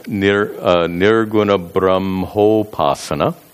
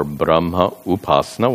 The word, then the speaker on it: nirguna brahmopasana: By the way, these words can be combined, and it's pronounced nirguna brahmopasana, if the words are combined. Brahmopasana or brahma upasana, when the words are separated.